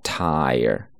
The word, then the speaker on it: tire: 注意是双元音加元音，发音的时候呢慢一些，饱满一些。 tire，tire。那比如说我车胎没气了，一个固定搭配叫做 flat tire。